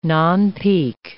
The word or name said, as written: nonpeak